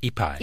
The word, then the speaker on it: Ipai: As early as 1772, Father Luis Jaime Orjamia of Mission San Diego reported hearing that local Indians, probably, is it Ipay or Taipei? Is that how you say it? – Ipai.